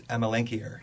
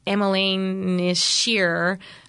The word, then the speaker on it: Amelanchier: How do you like to pronounce it? – It's Amelanchier. – Amelanchier. And then some call it Amelanchier, but regardless, that's the genus.